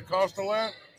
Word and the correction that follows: Costanetta: How do I say it? – Costanetta. – Costanetta.